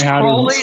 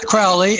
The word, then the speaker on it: Crowley: And it's Crowley, not Crowley, and it's UFOnauts, not UFOnauts.